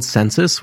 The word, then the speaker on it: Censys: It's called Census, which is spelled C-E-N-S-Y-S. – Census.